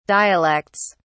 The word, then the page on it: dialects: British English